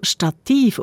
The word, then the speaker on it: Stativ: Stativ auf der zweiten Silbe, statisch auf der ersten. Und wenn jetzt also Stativ auf der zweiten Silbe betont wird, wieso der Dativ nicht?